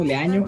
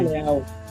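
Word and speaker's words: Leão: No sé cómo pronunciarlo, Leaño, no, porque tiene como el estrofa en la, como si fuera en la ñ, ¿no? Así, sí, Nara Leao, cantante pues de bossa nova, pues cantante y actriz, ¿no?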